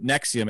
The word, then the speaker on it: NXIVM: I mean, the group Nexium, it's spelled NXIVM, which, by the way, that's red flag number one for me.